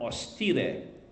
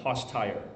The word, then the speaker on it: hostire: Okay, now, the other derivative from the Latin of host, which is ostia and victim, is the verb ostire, H-O-S-T-I-R-E. So it's spelled like hostire, but it's, you say ostire in Latin. Okay, don't say hostire. Then they'll know you don't speak Latin.